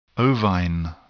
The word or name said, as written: ovine